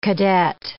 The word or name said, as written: Cadette